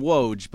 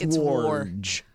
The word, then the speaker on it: Woj: It says we call him Woj, but it's War. – It's Worj. – I mean, it's a Polish name, and I guess that's how you pronounce it.